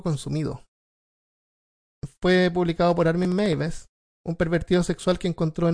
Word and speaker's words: Meiwes: El caso del alemán Armin Meiwes, que se pronuncia Meiwes. Armin Meiwes.